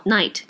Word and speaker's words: knight: マグギグでも出てきますが、えー、このナイトとナイトの掛けね、ナイト、夜のナイト、 N-I-G-H-T と、 night、えっ、と、騎士の night が、えー、 k-n-i-g-h-t、 knight って書いて night。発音は一緒ですね。